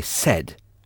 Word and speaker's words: said: Non, I said. I said. Ah. Ça s'écrit S-A-I-D. I said. S-A-I-D.